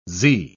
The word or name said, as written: zee